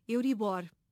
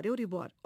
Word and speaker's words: Euribor: Filomena, em português, a pronúncia correta é Euribor, palavra aguda. – Uhum. Mas tem acento no O ou não? – Não. Okay. Não. Pronúncia Euribor.